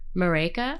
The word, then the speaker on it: Marÿke: Am I saying your name right? – Yeah. – Marÿke? – Yep, Marÿke. Like Jamaica.